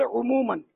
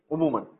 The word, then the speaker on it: عموماً: اس کا تلفظ ہے عموماً. – عموماً ٹھیک، ماشاء اللہ ماشاء اللہ اس کی بھی ہو رہی ہے ماشاء اللہ.